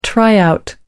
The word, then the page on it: try out: American English